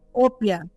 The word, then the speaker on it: Opia: Como é que fala a, a, o nome da sua, da sua marca? Eu não, não consegui pegar. – Opia. – Opia.